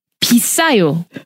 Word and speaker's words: 비싸요: You just have to add one syllable in front of 싸요, which is cheap. – Right. – Expensive is 비싸요.